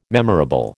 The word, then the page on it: memorable: American English